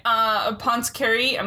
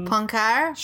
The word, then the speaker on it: Poincaré: And these were first idea-ized by Henry Poincaré. I'm butchering it. I'm sorry. – I want to see. – Poincaré. – Sure. Poincaré.